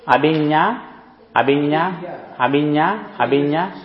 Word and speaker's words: Abhinna: A-B-H-I-N-N-A. – Abhinya Sutta. – Is the N the same as in Spanish? Is it an enye? – Yes, enye.